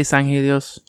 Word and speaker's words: Sanghelios: They pronounce it Sangelios in these books instead of Sanghelios. But I used to always pronounce it Sanghelios. – I always say Sanghelios. I'm pretty sure it's the way to say it in Halo 5 too.